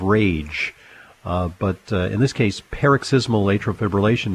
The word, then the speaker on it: paroxysmal atrial fibrillation: I have two questions for you regarding a diagnosis of, and I hope I pronounce this properly, paroxysmal atrial fibrillation. – Okay, let's go back to med school. I think it is paroxysmal atrial fibrillation.